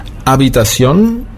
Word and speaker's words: Habitación: Das H ist stumm. Ja, das wird also nicht mitgesprochen. Habitación wird zwar mit einem H geschrieben, aber nicht ausgesprochen.